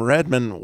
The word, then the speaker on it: Redmond: But now I'm even more blessed because Mark is calling from Redmond, Washington.